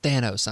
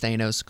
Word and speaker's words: Thanos: When I was in Nashville, somebody was like, yeah, you don't say Thanos right. It's Thanos. I'm like, okay, it's Thanos.